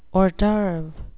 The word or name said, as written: hors d oeuvre